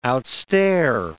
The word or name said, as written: outstare